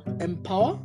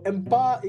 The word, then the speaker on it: Mpower: First of all, Mpower. – Yeah, you do. You do, exactly. – All right. So Mpower is a climate and a fintech startup.